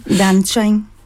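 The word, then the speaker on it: đàn tranh: Vietnam, musique d'hier et d'aujourd'hui, Le Dan Tran, est-ce que c'est bien ça? Mais on va le faire dire, le faire prononcer. Qu'est-ce que tu vois sur la deuxième ligne? – Dan Tran. – Ah oui, c'est mieux quand même. Oh, c'est beaucoup mieux.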